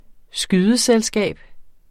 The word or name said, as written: skydeselskab